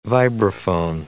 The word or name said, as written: vibraphone